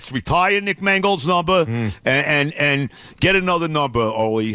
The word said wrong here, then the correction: Olu: How do you say his first name? – Olu Fashanu.